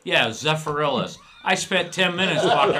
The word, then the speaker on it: Zephyrhills: It's not Zephyrhills. – Oh, now you know. – you're mispronouncing it. – How is it pronounced? – Zephyrhills.